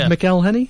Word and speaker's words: McElhenney: Rob McElhenney? – Yes.